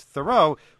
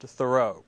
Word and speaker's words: Thoreau: It's not pronounced Thoreau. But most of us say it that way, and it's very hard to get it right. It is Thoreau.